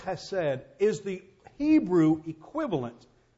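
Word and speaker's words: chesed (חסד): Instead, there was a word in the Hebrew that's pronounced chesed. You have to almost kind of spit when you say it, you know. You have to have a sinus condition like you grew up in Louisville, Kentucky. Chesed.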